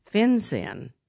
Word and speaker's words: FinCEN: And we're going to get into some regulations that are in place called FinCEN.